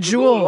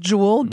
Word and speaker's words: Juul: J-U-U-L. – Bien, c'est Jewel, oui. Ouais, J-U-U-L, là, on le prononce Jewel, puis c'est la fameuse vapoteuse qui fait tant pester les Américains, et même chez nous ici, les pneumologues, comme Dr Gervais.